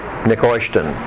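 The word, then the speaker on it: Nehushtan: But that's the way it's pronounced in Hebrew. Neoshtan.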